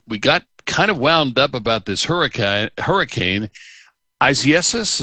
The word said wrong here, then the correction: Isaias: is that how do we pronounce that? – Isaias. And I was just talking to Scott about this. Isaias. I don't think I'm putting the pronunciation in quite right when I say it, but that's how you say it.